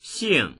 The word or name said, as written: xing4